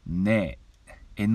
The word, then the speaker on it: NE: おります斉藤直也と申しますいつも聴いていただきどうもありがとうございます今音歌いましたのは川村隆一さんで「ねえ」「 NE」「ねえ」でございましたあちょっと、ま、紛らわしいですね「 NE」と書いて「ねえ」という曲